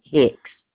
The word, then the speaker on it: Hix: My last name is spelled H-I-X, Susan Hicks.